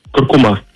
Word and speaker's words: куркума: Несмотря на то, что большинство произносит данное слово с ударением на последний слог, акцент в нем все же стоит на втором. Правильно говорить, куркума, представляете?